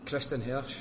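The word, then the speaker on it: Kristin Hersh: For me, like with the music, so, well, something that backs it up, so Kristen Hirsch, mm-hmm.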